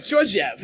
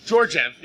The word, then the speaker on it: Georgiev: Now, I'm going to assume, it's Gorgiev or Georgiev, how do you pronounce it? – Well, great question. – Great question. – I'll go with Georgiev. – Yeah, Georgiev, yes.